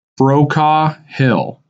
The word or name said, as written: Brokaw Hill